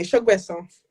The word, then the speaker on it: Sogbesan: Give me the Nigerian way. – Excuse me, Sogbesan.